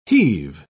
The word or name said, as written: heave